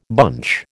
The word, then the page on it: bunch: American English